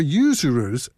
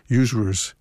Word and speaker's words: usurers: But for usurers, this is a hard word for a Philadelphian to pronounce, we would say usurers. Usurers. But I can't say that on the radio. Usurers. But for usurers, it's better to do more Scottish, usurers.